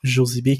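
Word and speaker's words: Josybee: Ou annuel 97 dollars et avec mon code promo Josibi, c'est moins 25 sur l'abonnement annuel et ça revient à 72 Josibi qui s'écrit J-O-S-Y-B-E-E. E.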